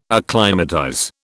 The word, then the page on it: acclimatize: British English